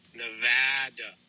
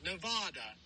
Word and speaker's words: Nevada: My home state, Nevada. It's not Nevada. It's Nevada.